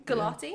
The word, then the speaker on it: Gulati: My star was actually um, Ray, who was played by Shobna Gultari. – Gulati? – Gulati. Yeah. Sorry, I we butchered that.